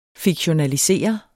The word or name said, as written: fiktionaliseret